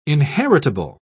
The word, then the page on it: inheritable: British English